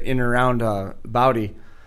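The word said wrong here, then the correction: Bowdle: I say that right? – Bowdoll. – Huh? Bowdoll. Okay. Sorry.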